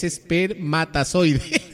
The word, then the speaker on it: espermatozoide: Y acá me escribe doña Nancy, dice, ya sé, ya me acordé, es espermatozoide. – A ver, señora Nancy, embrague. A ver, pronuncie. Es la E con la S. P-R-E. Mamarre, mentira. Espermatozoide, así se dice.